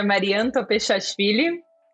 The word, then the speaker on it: Mariam Topeshashvili: É, bom, meu nome é Marian Topechashvili.